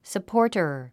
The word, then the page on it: supporter: səpɔ’ː r tə r; サポーター